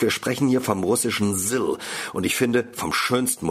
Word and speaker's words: ZIL: Es schreibt sich in Deutsch also Z-I-L, spricht sich aber Sill.